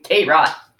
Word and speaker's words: tarot: I've been reading tarot cards longer than you knew how to pronounce it without the T at the end. – Tarot.